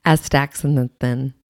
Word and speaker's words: Astaxanthin: Astaxanthin.